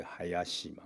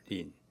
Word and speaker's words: 林: 林呐哈林，那咱在林百货，各种林百货叫做海亚西嘛哈，海亚西，那你也可以要求这个日本政府说，我不要读成海亚西，我要读成林啊林，而是林啊，那台湾人当然是有这林然后中国人才变林啦哈，啊林啊安尼安尼买晒啊，哈你也当要求是安尼啦哈，嘿哦啊我我即么是安尼哦。